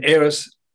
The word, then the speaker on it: Ayers: In Australia, we have the Ayers Rock. You say pronounce Ayers, right? Ayers Rock, that's right.